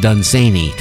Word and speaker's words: Dunsany: I believe, and I'm more certain about this than other things that I've said, that his name is pronounced Dunsany, to rhyme with Cheney.